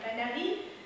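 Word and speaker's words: panaris: La palarie, c'est au singulier, ça prend un S, mais le S ne se prononce pas, c'est un S muet. Donc, ça s'écrit avec un S, mais ça se prononce palarie, c'est pas palaris.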